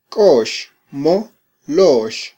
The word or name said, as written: kox mo lox